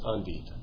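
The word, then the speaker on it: endive: I thought that my wife has told me it's pronounced Andit.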